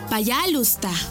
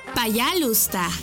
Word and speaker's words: пожалуйста: Пожалуйста. ¿Cómo? Пожалуйста. Una vez más. Пожалуйста. Y se escribe Payalusta. P-A-Y-A-L-U-S-T-A.